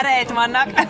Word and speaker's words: Reitman: Ivánnak mondtam volna. Iván Reitmannak. Csak ilyen németesen. – Különben jó az, hogy minden névnek legyen egy magyar kiejtése. – De nem, ez egy német kiejtés.